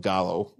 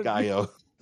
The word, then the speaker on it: Gallo: Angry wife gets mad at husband's pronunciation of Gallo. – It's wrong. – Gaio.